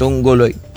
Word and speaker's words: Tunggol: Attorney Reginald Tunggol.